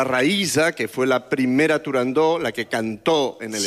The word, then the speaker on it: Turandot: No se debe pronunciar Turandot. – Turandó. – La ópera se titula Turandó.